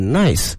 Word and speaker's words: Nice: Then I'm going to take the train to 这个尼斯啊，它的拼写跟那个 nice 一样的哈，我们非常好，但是发音呢不能念成 nice，而是什么？ Nice，去完了尼斯之后呢，它下一站是米兰。